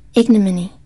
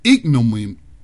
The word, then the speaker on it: ignominy: So let me see how you pronounce this. – Ignominy. – Ignominy. I can't even say it.